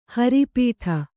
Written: ಹರಿಪೀಠ

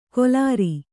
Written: ಕೊಲಾರಿ

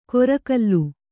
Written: ಗೋರ್ಕಲ್ಲು

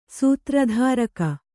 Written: ಸೂತ್ರಧಾರಕ

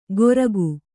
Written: ಗೊರಕು

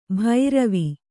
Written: ಭೈರವಿ

